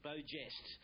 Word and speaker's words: beau geste: He said the correct way to say beau geste is beau geste.